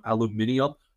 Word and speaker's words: aluminium: Also, shout out to all you British people who pronounce aluminum aluminium. I think it is the funniest thing of all time. That's like one of the good things to come out of Britain or come out of England in all of history, but it is absolutely hysterical. I'm going to stick to the American pronunciation today.